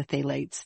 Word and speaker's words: phthalates: And he was talking a lot about the phthalates that are in the ocean. I think I'm pronouncing that correctly. – Yep.